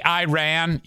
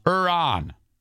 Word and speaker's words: Iran: And by the way, if you say Iran, you're saying it wrong. It's Iran. Iran.